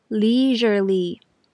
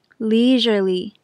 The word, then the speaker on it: leisurely: 英文里这个音其实来自于法文，例如 Je t'aime，我爱你，这个 R 的音小心不要念成 J，是 R R leisurely leisurely。